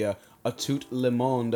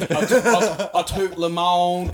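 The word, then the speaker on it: à tout le monde: A Tout Le Monde Megadeth beer, and definitely a step up from the Metallica, so is it? – Dave Mustaine, you win again, my friend.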